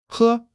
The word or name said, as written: he1